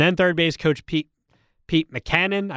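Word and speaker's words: Mackanin: I'm going to look up your pronunciation. We're going to find it. It is McCannon. Yeah, I said it right.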